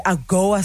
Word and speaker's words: AGOA: The AGOA Summit.